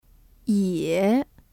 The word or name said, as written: 也